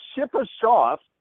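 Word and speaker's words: Shipachyov: Even I had the pronunciation in an email, and it's Shipashoff. That's how you say it. But I've heard Shipashoff And I guess it's a matter of how much emphasis you put on the AU pronunciation, right, in the middle, even though that's not the spelling.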